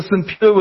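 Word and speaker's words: Siphiwe: It's a Simpiwe with an H, not without the H.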